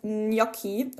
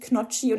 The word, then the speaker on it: Gnocchi: Leute, das heißt Gnocchi und nicht Knocchi und das heißt Bruschetta und nicht Bruschetta.